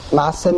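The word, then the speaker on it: ma'aseh (מעשה): Ma'aseh means man's actions. It's ma'aseh, and it's mila'el. It's not mila'ra. The accent is on the men, not on the sin.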